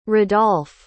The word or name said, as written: radolph